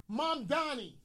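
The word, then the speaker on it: Mamdani: Can you pronounce it again? – Mom Donnie.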